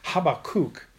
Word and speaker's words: Habakkuk: But really, the Hebrew pronunciation of this word, its name, is Habakkuk.